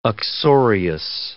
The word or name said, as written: uxorious